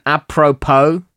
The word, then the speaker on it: apropos: Apropos.